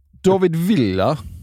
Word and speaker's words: David Villa: David Villa. Bra uttal.